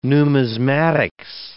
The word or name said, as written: numismatics